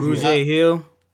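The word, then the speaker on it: Grugier-Hill: Gregaire Hill? Is that how you pronounce that correctly? – Kamu Grugier Hill. – Oh, yeah. Wow. I was way off.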